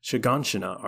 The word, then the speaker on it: Shiganshina: Well, it takes place 4 years after the return to Shiganshina arc.